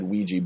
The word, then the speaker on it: Ouija: the guy's real specific about how it's pronounced. I always said Ouija board, but the guy in the movie is very specific that you have to say, like, Ouija.